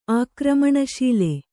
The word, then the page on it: ಆಕ್ರಮಣಶೀಲೆ: ākramaṇaśīle